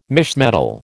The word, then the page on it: misch metal: American English